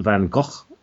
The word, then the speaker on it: Van Gogh: In this episode, the Doctor, Amy, and, of course, Vincent Van Gogh, and that's how you say it, and a terrified alien all feature in this stunning but heartbreaking adventure written by Richard Curtis.